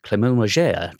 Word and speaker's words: Clément Légère: on Straight Ahead this week, our guest is guitarist Clément Légère. How's my pronunciation, first of all? – You sound more French than me, wasn't it?